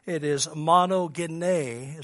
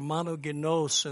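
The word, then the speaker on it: monogenes: In the Greek, it is monogene, is how you say that word, or monogenos in another form.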